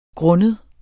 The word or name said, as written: grund